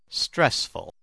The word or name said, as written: stressful